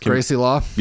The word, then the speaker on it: Gracie Law: We have Kim Cattrall as Gracie Law. Did I say that right? – Yes, Gracie Law.